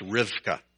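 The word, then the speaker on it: Rivka (רבקה): This is what Rebekah's name is in Hebrew. It's pronounced Rivka.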